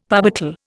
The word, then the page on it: parbuckle: Indian